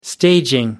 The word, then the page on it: staging: American English